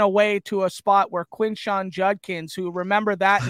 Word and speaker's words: Quinshon Judkins: So uh, Quinshon Judkins is how you pronounce the name.